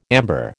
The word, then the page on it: amber: American English